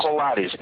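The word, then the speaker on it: Colares: Recently I've been reading a lot about a situation in an island, and correct my pronunciation of this, Colades. – That's perfect. Colades, that's the name of the island.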